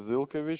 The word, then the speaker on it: Ilkevich: Alexander Ilkovich. – All right, give me one second, sir. You said that first name is Alexander. – Yeah, I'll spell it for you. ALEKSANDR. And the last name is Ilkevich.